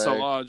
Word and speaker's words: Saleh: overall team defense is Robert where how do you say his name? – Salah. I think Saleh, it's Salah, just like the soccer Salah, player.